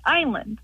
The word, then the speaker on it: island: Why is it island always spelled like island, and why do we always pronounce it like island? – Oh, what a good question. Why does island have an S in it, and why is it pronounced with a long I?